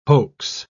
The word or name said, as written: hoax